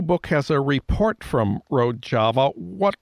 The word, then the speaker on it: Rojava: And the New York Times reported last fall about this autonomous Kurdish region called Rojava. Am I pronouncing that right? – Rojava, yeah.